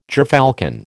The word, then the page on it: gerfalcon: American English